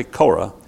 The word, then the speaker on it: Korah: That's actually Korah. The E is pronounced like a long A, like an A.